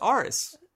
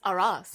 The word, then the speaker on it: Aras: I even learned it's not pronounced Aris. – It's Aras.